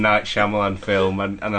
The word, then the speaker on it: Shyamalan: It's Shyamalan. – Shyamalan.